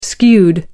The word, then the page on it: skewed: British English